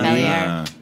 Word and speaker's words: Velier: Yeah, it's an Italian company, though. So you pronounce the R. Vellier.